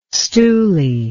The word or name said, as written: stooly